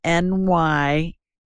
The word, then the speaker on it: nystatin: And it's spelled NYSTATIN, nystatin. Think of New York and Staten Island. That's how it's an easy way to remember it.